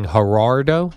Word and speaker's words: Gerardo: So you're saying Gerardo? – No, I'm not saying it. That's how he pronounces it.